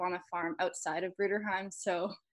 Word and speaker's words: Bruderheim: Now, I'm probably going to butcher this, but I know you're from, is it Bruderheim, Alberta? – Bruderheim, yeah.